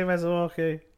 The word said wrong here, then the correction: Masuaku: Uh, Masuaku. Uh, pretty much the same as you, I think. – I, I would say Masuaku.